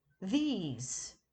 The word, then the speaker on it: these: This is pronounced with the short I, as in kiss, this, whereas these is pronounced with the long I, as in keys, these.